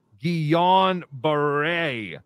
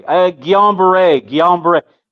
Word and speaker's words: Guillain-Barré: Chris Mack, it's pronounced Guillain-Barré syndrome. Shake my head. Exactly. Guillain-Barré. You know, it's two guys and two French guys came right around World War I.